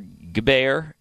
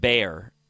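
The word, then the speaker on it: Gbayor: Nebraska linebacker Makai Bayer, or Gbayer. It's G B A Y O R, but I think the G is silent. Makai Bayer.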